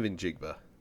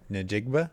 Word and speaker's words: Njigba: So the N is silent. – No, it's not. It's sort of, but it's not like a N. It's not Njigba. It's an N. – Njigba. – Njigba. Njigba. Like with like Nduya sausage.